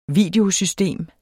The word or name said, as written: VIDEOSYSTEM